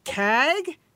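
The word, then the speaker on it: KAG: CAG rhymes with gag.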